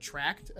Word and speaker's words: Trakt: look up the app called Tracked. It's spelled, where is it? T-R-A-K-T.